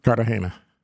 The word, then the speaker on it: Cartagena: Is it Cartagena, Colombia? – Cartagena.